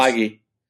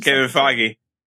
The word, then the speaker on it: Feige: So Disney Plus, if you're listening, Kevin Fergie and everybody else. – Feige. You got to get his name right when you're pitching. – Kevin Feige.